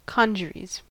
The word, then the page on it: congeries: American English